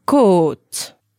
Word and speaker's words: côte: For example, une côte. – Une côte. – It's spelled C-O with an accent, circumflex, T-E, and that means a rib.